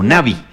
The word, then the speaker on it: Navi: Es de Sudáfrica, la conocen así, Navi, como Navi.